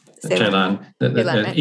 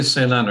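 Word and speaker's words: Celan: This is by, you pronounce it Ceylan? Ceylan? – Ceylan. Either Ceylan or Ceylan if you use a Romanian pronunciation or a German pronunciation.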